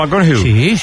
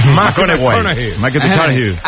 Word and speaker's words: McConaughey: Mateo McConaughey. – Sí. – Que hay que ir corrigiendo la pronunciación. – McConaughey.